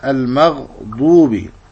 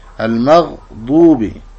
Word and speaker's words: المغضوب: كذلك الضاد في قوله تعالى المغضوب، فتخرج من حافة اللسان اليمنى واليسرى مع ثنايا الحنك الأعلى المغضوب.